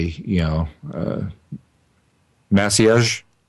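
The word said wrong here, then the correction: Maciej: of Pinboard, I hope I'm pronouncing his name right. – It's pronounced Machai.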